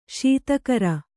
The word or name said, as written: ಶೀತಕರ